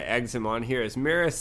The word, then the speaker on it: Maris: So is the girl's name Maris? I want to make sure I say it right. Is it Maris? Is how you say it? M-A-R-I-S, the one that he was, I guess, the daughter of Lord Boros, I guess is his name, right? – Yes, Lord Boros, but I believe Maris is the name of the daughter. I think that's how it's pronounced, yes.